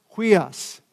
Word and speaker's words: huios: It's pronounced, it's kind of tough to pronounce, H-U-I-O-S, huios. It's pronounced huios, H-U-I-O-S, but it's important.